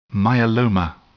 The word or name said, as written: myeloma